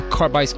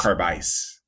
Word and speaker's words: Carbice: So think about going to the bar, and it's the combination of carbon and ice. – ice. Okay, so it is carb ice. – Carb ice.